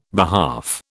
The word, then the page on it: behalf: British English